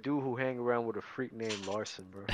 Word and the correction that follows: Larsa: Why do you keep saying Larson? – That's how you say it, Larsa or Larson? – It's Larsa.